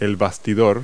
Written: baster

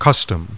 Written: custom

